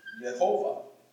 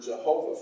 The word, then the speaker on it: Jehovah: And so they inserted these vowels to be able to pronounce this word in this sense, Yehovah. And of course, a German would pronounce this Yehovah. Yehovah. And then in English, we get the word Jehovah from that.